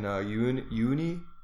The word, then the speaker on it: Uyuni: People go down to Bolivia for the world's biggest in Uyuni.